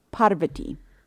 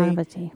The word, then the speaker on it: Parvati: So in this thing, she was like, her name is pronounced Parvati. So now I always try to pronounce it correctly. – Parvati.